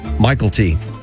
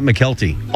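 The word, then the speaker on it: Mykelti: I discovered last week that's how he pronounces his name. – How? – Michael T. Not McKelty. All these years, I thought it was McKelty.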